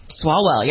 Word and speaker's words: Swalwell: Swalwell, yep, he's out.